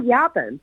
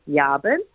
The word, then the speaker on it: Yabun: It's called Yaban. And if you look it up online, it's spelled Y-A-B-U-N, Yaban.